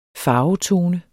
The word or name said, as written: farvetone